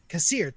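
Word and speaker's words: Kassir: And then you got and I always can never pronounce his last name correctly. John Kasser? – Oh, Kasser, yeah. – Kasser, thank you.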